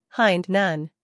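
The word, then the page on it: hind: uk haɪnd; en-us haɪnd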